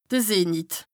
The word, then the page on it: de Zenit: Bas-Rhin; Strasbourg